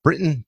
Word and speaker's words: Britton: Well, today we have a very special guest, Britton Turkett.